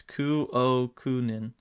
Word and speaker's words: Kukkonen: But I'm looking at the pronunciation here on Hockey Reference. It's Kuokunen. – Oh, okay. – Kuokunen. You know, maybe that's wrong, but that's what it is here.